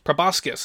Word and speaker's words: proboscis: Of the things I didn't expect to learn from this interview was that I've been mispronouncing proboscis for as long as I have, that it is, in fact, pronounced proboscis, so learn something new every day.